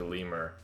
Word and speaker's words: lemure: Oh, so the next one is called a lemur or lemur. L-E-M-U-R-E.